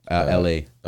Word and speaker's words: Elhae: It's spelled E L H A E. – Wait, that's L A.